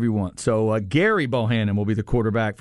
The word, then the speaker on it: Gerry: Hey, by the way, we do have to correct the pronunciation of Bohannon's first name. Apparently, even though it's spelled G-E-R-R-Y, it's Gary.